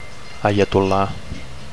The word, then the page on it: aiatolla: Catalan